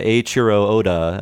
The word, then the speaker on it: Eiichiro Oda: And so the manga for One Piece, it started in 1997 by Eiichiro Oda.